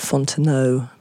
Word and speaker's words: Fontenot: Before that, Pauvre Hobo, that is a song by a Cajun guitarist called Richard Fontenot, or Richard Fontenot, probably is more accurate pronunciation.